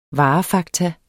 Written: varefakta

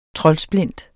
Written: troldsplint

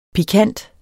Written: pikant

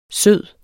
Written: sød

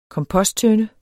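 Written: KOMPOSTTØNDE

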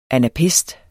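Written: anapæst

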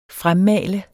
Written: fremmale

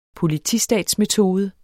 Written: POLITISTAT

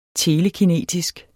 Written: telekinetisk

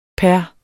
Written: per

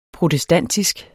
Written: protestantisk

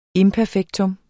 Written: IMPERFEKTUMMETS

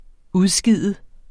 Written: udskide

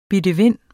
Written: bidevind

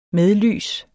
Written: medlys

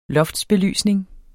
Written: loftsbelysning

